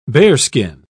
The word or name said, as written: bearskin